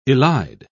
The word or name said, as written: elide